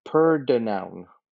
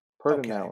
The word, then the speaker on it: Pordenone: Sorry, it's pronounced Pordenone. Pordenone.